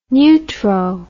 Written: neutro

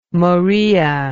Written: moraea

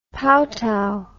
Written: paotow

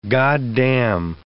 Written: goddamn

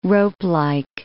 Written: ropelike

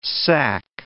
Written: sac

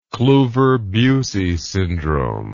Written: kluver bucy syndrome

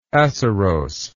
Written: acerose

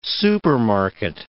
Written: supermarket

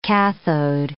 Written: cathode

